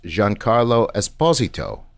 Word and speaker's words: Giancarlo Esposito: that his name is pronounced Giancarlo Esposito. – Yep. – And we've been putting the emphasis on the wrong.